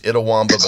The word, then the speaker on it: Itawamba: Actually, it's Itawamba, but that is a tough one to pronounce.